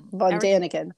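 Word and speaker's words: Däniken: Did I say his name right? Because you know me as Van Daniken. – Van Daniken, yeah.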